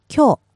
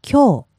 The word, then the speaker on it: 今日: 今日. – Yes. One more time, please. – 今日.